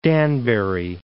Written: Danbury

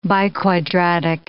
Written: biquadratic

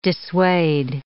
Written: dissuade

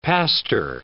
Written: pastor

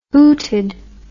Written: hooted

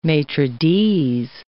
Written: maitre d's

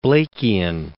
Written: Blakean

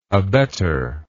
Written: abettor

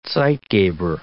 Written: zeitgeber

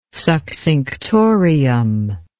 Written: succinctorium

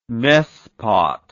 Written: mespot

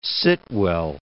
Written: Sitwell

